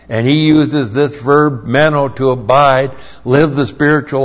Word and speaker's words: meno: And the other word is meno. M-E-N long O.